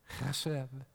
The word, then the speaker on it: chesed: Chesed. And he says, love it. Love chesed. Love chesed, if you like. It's like spelled, many spell it, H, a hard H-E-S-E-D. But it's pronounced like a guttural K.